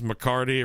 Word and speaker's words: McCarty: His last name is McCarty. It rhymes with Guitardy.